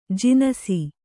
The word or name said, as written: ಜನಿಸು